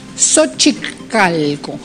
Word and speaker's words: Xochicalco: Xochicalco, así se pronuncia. Todo lo que es X, pueblos originarios, Xochi, como Xochimilco, también un lugar turístico en México.